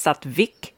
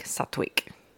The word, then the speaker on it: sattvic: And you might hear it pronounced sattvic. It's spelt with an English V often in English letters, and people will differ in their pronunciation. I like sattvic, more of a wut sound.